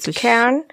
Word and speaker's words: Pitcairn: Das hört Pitcairn.